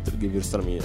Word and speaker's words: Gewürztraminer: Solo 10 anni fa nessuno sapeva cos'era il Gewürztraminer. – Tra l'altro, per far contento anche Gabriele, farei notare la pronuncia, sono stata perfetta.